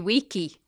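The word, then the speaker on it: vici: Weeny, weedy, weechy. – No, that's the hard C, remember? Weeny, wiki. Weedy, weeky.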